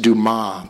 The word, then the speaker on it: Dumas: And Dumas is pronounced Dumas, by the way. It's French.